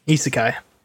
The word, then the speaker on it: Isekai: after we find the reverse Etsukai, am I saying that right? – Isekai.